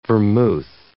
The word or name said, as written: vermouth